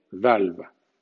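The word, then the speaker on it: valve: Sin embargo, en V-A-L-V suena la L, VALVA, ¿A qué se debe esto? Se debe a que la palabra entró en inglés desde el latín y entró con la pronunciación latina. Un poco más adelante veremos cómo algunas excepciones se deben a la introducción de palabras latinas y francesas.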